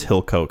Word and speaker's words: Hillcoat: Oh, I guess it is Hillcoat because it's spelled like coat.